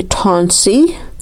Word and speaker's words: Tansi: Say hello, you say Tansi, T-A-N-S-I with a long A.